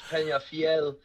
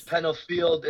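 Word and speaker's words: Peñafiel: It's Penafiel. It's Penafiel.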